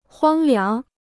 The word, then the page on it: 荒凉: huāng liáng